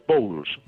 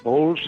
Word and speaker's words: Bowles: Bueno, mi apellido es Bowles. Bowles se escribe B-O-W-L-E-S, un apellido ilustre, ¿no?